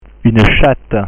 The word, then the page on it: une chatte: French